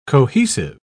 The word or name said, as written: cohesive